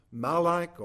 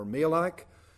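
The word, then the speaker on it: malak (מלאך): In the Old Testament, the Hebrew word is pronounced malak or malak.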